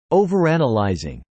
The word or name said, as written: overanalyzing